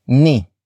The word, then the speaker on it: ni: It is followed by ni, which is spelled as N I.